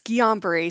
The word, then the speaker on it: Guillain-Barre: It's Guillain-Barre syndrome. It's a rare autoimmune disorder and, it's French in case you're trying to pronounce it.